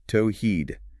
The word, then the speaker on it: Tawhid: In the framework of Islamic theology and divine oneness, the Arabic term is Tawhid, pronounced Tawhid, God, the ultimate, unconditioned good.